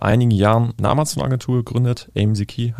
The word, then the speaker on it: Amzkey: Aber lass uns mal kurz über AMZKey, wird das mhm. – ausgesprochen, mhm. – A-M-Z-K-E-Y geschrieben.